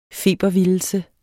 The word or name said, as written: febervildelse